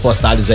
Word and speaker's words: Postales: Também o Postales, que faz sucesso pelo preço. Apenas R$39,99. – Repete o Postales aí. Como é que fala? – Postales.